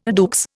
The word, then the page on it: à deux: Indian